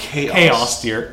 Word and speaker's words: chaos: Chaos, dear. Chaos. Chaos. You don't pronounce C-H cha in, when it's chaos.